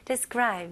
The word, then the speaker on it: describe: Describe, 묘사하다 라는 뜻을 가지고요. 발음 연습해 볼게요. 여기서는 D-E이지만 여기도 여전히 I 발음으로 발음해 주시면 훨씬 더 발음하기 쉬울 거예요.